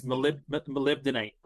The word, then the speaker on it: molybdenite: How do you pronounce that? Molly, but a banana? – You nailed it. No, it's molybdenite.